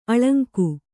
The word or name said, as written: ಅಲಂಗ